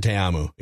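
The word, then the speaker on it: Ta'amu: Is it Jordan Te'amu? I believe is how you pronounce it. – Oh, sure. – Yep. Jordan Te'amu.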